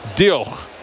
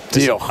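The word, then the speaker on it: Diolch: That is how you say it. Dioch.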